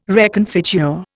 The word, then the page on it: reconfigure: Indian